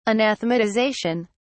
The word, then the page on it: anathematization: British English